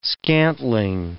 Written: scantling

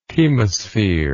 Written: chemospheric

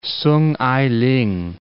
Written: Soong Ai-ling

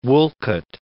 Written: Wolcott

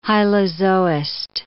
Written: hylozoist

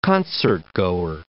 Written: concertgoer

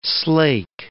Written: slake

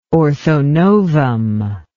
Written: orthonovum

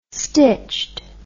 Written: stitched

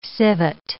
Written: civet

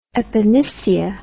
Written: epinicia

